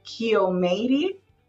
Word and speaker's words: Kiomeiry: And it is Kiomeiry is the first name, and the last name is Csepes.